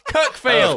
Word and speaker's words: Cuckfield: Like, it's pronounced Cuckfield. – Of course they are. – But it is spelt Cuckfield.